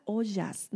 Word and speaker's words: ojas: Luego tenemos a ollas. No sé si han escuchado ollas, se escribe O-J-A-S.